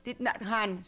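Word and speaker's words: Thich Nhat Hanh: Este Thich Nhat Han, o cómo se escribe, Tich Nhat sí está bien pronunciado, Thich Nhat Han, sí está bien.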